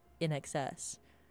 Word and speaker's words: INXS: So INXS is a phonetic play on in excess. So I think it's supposed to be pronounced in excess, but they're just like the letters in all caps, INXS.